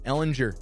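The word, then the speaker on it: Ehlinger: I've heard both. Ellinger and Ellinger. – Ellinger. – Thank you. Ellinger. All right, with the hard G.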